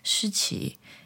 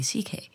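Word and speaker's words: Shiqi: I was like, well, there are two ways to pronounce it. In Mandarin, you can say 시치. In Cantonese, you can say CK.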